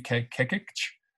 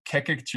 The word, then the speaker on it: Kekich: It's called Master Life, and it's by Dave Kekich. And Kekich is spelled K-E-K-I-C-H.